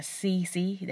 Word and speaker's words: CeCe: So my real name is Sierra, but I also get called by CC. That's spelled C-E-C-E.